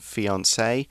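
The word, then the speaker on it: fiancé: So you'll see in our article we've got that French accent over the E, which gives it the pronunciation fiance. Um, if it's a girl, then you add an extra E. It's got mm. two E's on it. So fiance, fiance, I think it's pronounced the same, right? – Yeah, as far as I know.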